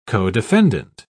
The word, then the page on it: codefendant: British English